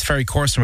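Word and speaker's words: Ferry Corsten: I suppose I was a fan from the late 90s when you were with Ferry Corson with Goriella. Have I pronounced that correctly? – Yeah.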